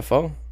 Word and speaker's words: pho: Isn't it pho? – It's pronounced pho, actually.